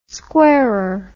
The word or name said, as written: squarer